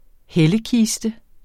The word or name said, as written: hellekiste